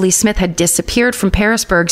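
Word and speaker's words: Pearisburg: Parisburg, but it's like the fruit pear, not Paris, Paris, France, but Parisburg. And I'm probably pronouncing it wrong.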